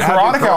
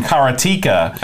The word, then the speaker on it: Karateka: And Karateka also came out, which is the real pronunciation, Karateka came out.